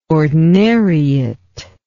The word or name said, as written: ordinariate